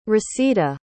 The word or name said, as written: rasida